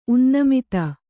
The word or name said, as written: ಉನ್ನಮಿತ